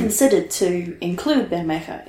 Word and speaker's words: Bermeja: Bermeja. – Bermeja, yes.